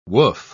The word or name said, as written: woof